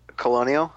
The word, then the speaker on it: Colonial: Aliens, how do you say that? Cornell Marines? Cornell? How do you say that? – Colonial.